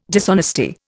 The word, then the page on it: dishonesty: Indian